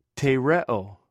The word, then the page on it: threw: greek